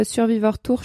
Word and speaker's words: Survivor Tour: Et ensuite, euh, je vais présenter mon Tour de France à pied, euh, que j'ai appelé Survivor Tour, mais euh, j'aime bien le prononcer en français, euh, Survivor Tour, je trouve ça splendide.